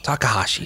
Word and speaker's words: Takahashi: Immerse yourself in the creative mind of Keita Takahashi. – Takahashi. – Sorry. – Come on. – I missed a syllable.